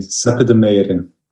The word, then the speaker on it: Seppe De Meyere: I've definitely said that wrong. I'm very sorry. – That was quite right, actually. Seppe de Meyere.